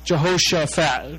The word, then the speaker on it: Jehoshaphat: Jehoshaphat, okay? That's how you say it.